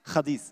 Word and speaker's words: Hadith: Und ähm, ja, und dann gibt es da noch nach dem Koran noch eine, eine, zweite Schrift, das ist die Hadith, habe ich das richtig ausgesprochen? Hadith, Hadith. Chadis, ja, genau.